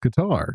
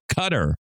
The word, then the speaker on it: Qatar: I always thought it was Qatar. – Yeah, so did Glenn Campbell. But it's a Qatar. That's how you really say it. By the way, do you know how you say P-A-K-I-S-T-A-N? – Pakistan.